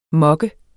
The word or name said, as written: mokke